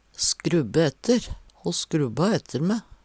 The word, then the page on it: skrubbe ætter: Numedalsmål